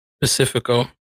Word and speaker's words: Pacifico: I'm drinking the beer I can never pronounce correctly, dude. The Pacifico. – There you go, you got it.